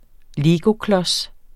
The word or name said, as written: legoklods